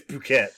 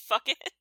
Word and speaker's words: Phuket: But apparently it's Phuket, so. – Yeah. – Sorry. Sorry, Thailand. – You thought it was actually pronounced Fuck It? – No, I'm sure it was.